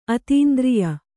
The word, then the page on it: ಅತೀಂದ್ರಿಯ: atīndriya